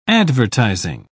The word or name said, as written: advertising